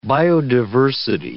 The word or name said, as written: biodiversity